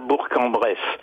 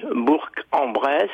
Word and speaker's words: Bourg-en-Bresse: Il faut dire Bourg-en-Bresse. Bourg s'écrit avec un G, mais le G, qui vient du latin, se prononce je dans les dérivés, comme bourgeois, mais se prononce que quand il est assourdi. On dit Bourg-en-Bresse, alors qu'on dit un bourg, sans prononcer la consonne finale, exactement comme on dit un porc-épic, alors qu'on prononce un porc.